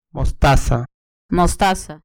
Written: mostaza

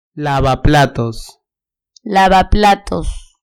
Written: lavaplatos

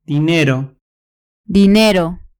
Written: dinero

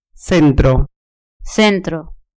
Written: centro

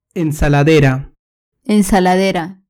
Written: ensaladera

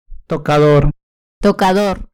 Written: tocador

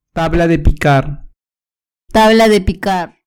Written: tabla de picar

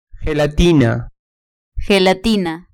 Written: gelatina

